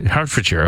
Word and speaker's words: Hertfordshire: Hertfordshire, okay. – Yeah. – Why do you say sure when it's shire? – And what? I don't know. It's sure. – Sure. – Hertfordshire.